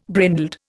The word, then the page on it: brindled: Indian